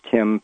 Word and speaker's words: Tymn: But I would like to say that if anybody's interested in the books, when you say my last name is Tim, people are inclined to might put T-I-M into a Google search or whatever. My last name is spelled T-Y-M-N. It's an offshoot of the word him, H-Y-M-N.